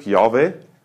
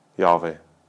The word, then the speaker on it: Jahwe: Sie sagen, wenn man, mir ist bis heute übrigens eben nicht sicher, wie man das Wort überhaupt ausspricht. Das ist momentan Mehrheitsmeinung, Jahwe. Wie so spricht man es auf, aber das, ist, das ist, wissen wir leider nicht ganz genau.